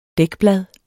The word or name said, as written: dækblad